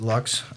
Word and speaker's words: Luyckx: That very weirdly spelled last name, L-U-Y-C-K-X. – Just like it sounds. – Exactly. But you just pronounce it Lux.